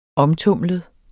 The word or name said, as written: omtumlet